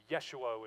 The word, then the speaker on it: Yeshua: Yeshua would probably be how we'd pronounce it in Hebrew.